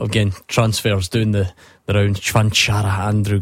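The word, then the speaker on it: Čvančara: Stephen, Thomas Chvanchara, we're reliably informed that's how you say it.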